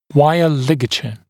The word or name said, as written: wire ligature